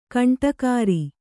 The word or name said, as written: ಗೂಂಡಾಗಿರಿ